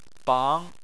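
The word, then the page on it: bang: tone 2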